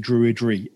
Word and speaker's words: Druid: Well, you know, the word Druid in a way says it all, because it's made up of two syllables, Drew and Id.